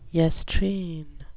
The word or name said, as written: yestreen